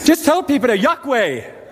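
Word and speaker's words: Yokwe: Just tell the person by the side of you, say, Yahweh. Say it one more time. Say, Yahweh. It is spelled Y-O-K-W-E. Y-O-K-W-E. That's how they spell it.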